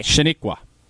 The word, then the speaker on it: Chenequa: How do you say C-H-E-N-E-Q-U-A? – Shiniqua.